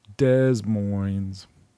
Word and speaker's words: Des Moines: Duh, moin, moin. Rhymes with loin. Des Moines.